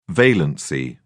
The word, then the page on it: valency: British English